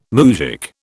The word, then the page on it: moujik: British English